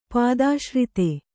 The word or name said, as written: ಪಾದಾಶ್ರಿತ